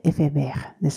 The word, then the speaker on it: éphémère: これ S のついた形ですが、単数形だとこの S がなくて、同じ発音でエフェメールですね。